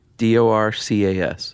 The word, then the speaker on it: Dorcas: There's only one way to pronounce that, right? – Yeah. – D O R C A S. Dorcas.